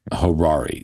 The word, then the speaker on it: Harari: And according to Harari, I think that's how you say his name, Harari. – Yeah, I always mess his name up. I guess it's probably just a mental block or something there. Harari. How you say it again, please? – Harari, Yuval Harari.